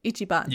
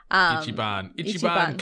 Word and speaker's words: Ichiban: Ichigo, if I say that right, which means number one, right? Ichiban, yeah. – Sorry. – Ichiban Kasuga.